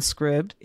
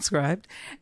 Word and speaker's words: Scribd: This is a short form book now out on Scribd. If you didn't know, it's pronounced Scribd and not Scribed.